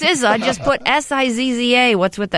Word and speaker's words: SZA: Oh, is that how you say it's SZA? S-C-A, it's yes. – SZA? – Yeah. Yeah, so like rappers from Wu-Tang Clan, RZA and GZA, she got her name from the same similar way.